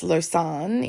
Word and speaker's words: Lausanne: So the city of Lausanne, which is spelt like law, Sanny, but it's Lausanne in Switzerland on its kind of western side.